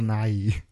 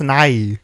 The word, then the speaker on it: Nighy: It's pronounced Nighy. – Nighy. – I thought you were saying. – You gotta say it slower. – Yeah, I thought you were saying Bill Nye, like weird.